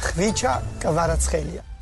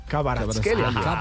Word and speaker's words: Kvaratskhelia: El goleador, yo le digo Barachkelia. Vicha Barachkelia. – Karabaskelia. – Vea cómo se pronuncia. – Vicha Kvaratskhelia. – Kvaratskhelia. Y es la voz de él, porque tanta gente le preguntaba que él mismo tuvo que aclarar.